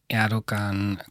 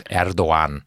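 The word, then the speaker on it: Erdoğan: Beginnen wir mal mit der Person Erdogan. Kannst du mal ein bisschen schildern? – Das G spricht man, weil es ein Hackerl drauf ist, wie ein J aus. Erdogan.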